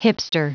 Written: hipster

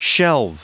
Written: shelve

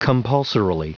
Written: compulsorily

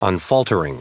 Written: unfaltering

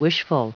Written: wishful

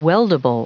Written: weldable

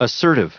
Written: assertive

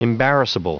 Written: embarrassable

